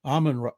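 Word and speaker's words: Amon-Ra St. Brown: Everybody's calling it says uh, Amon Ra St. Brown. I looked up on the internet the pronunciation, they call it Amon Ra, um, uh, Amon Ra St. Brown.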